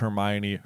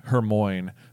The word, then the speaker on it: Hermione: I'll also point out, on this exact same note, that this is the chapter where we meet Hermione, we also called Hermione Hermoine for I don't know. – Until the movies. – Yeah. – We had no idea how to pronounce it.